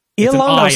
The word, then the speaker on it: Ilongo: Yango? – I think it's Ilongo. – Ilongo. So that's an I-L. It looked like two L's.